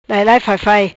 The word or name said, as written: Lailai Faifai